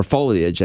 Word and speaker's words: foliage: Or foliage, I guess, is the correct pronunciation.